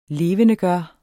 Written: LEVENDEGJORTE

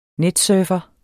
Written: netsurfer

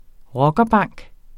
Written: rockerbank

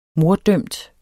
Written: morddømt

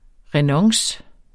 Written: renonce

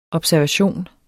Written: observationer

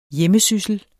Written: hjemmesyssel

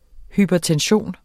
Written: hypertension

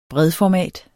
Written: bredformat

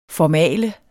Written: formale

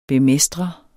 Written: bemestre

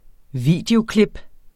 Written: videoklip

